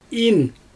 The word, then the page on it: in: Xyzyl